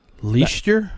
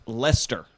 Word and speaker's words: Leicester: Leicester? – I would say Leicester. – Okay, so it's spelled L-E-I-C-E-S-T-E-R. L-E-I-C-E-S-T-E-R. It's pronounced Lester.